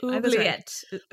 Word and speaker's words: oubliette: Oubliette pronunciation. Oubliette. – Yep, oubliette.